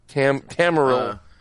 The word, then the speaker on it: Tamriel: Tamriel. T-A-M-R-I-E-L. – Tamriel. I think it's Tamriel.